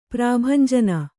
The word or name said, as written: ಪ್ರಭಂಜನ